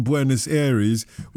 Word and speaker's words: Buenos Aires: But is it possible that if she'd watched it in a bar in Buenos Aires, which of course rhymes with Paul Serra's, that she'd have had a different opinion? – We don't care what the fair is.